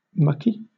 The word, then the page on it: mucky: British English